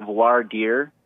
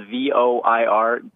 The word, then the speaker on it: voir dire: It's VOIR. And then a second word, D-I-R-E, it's pronounced voir dire.